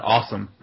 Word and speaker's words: OSSIM: And basically this article is talking about this type of software called an OSSIM, or I like to pronounce it awesome myself.